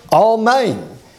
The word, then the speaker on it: amen: And the Hebrew word is spelled A-M-E-N, just like in English. According to Strong, it is pronounced Almain.